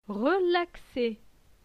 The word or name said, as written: relaxé